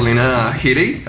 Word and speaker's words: Lena Headey: es la hija de Scarlett interpretada por Lena Headey, así es como se pronuncia, Lena Headey de Game of Thrones.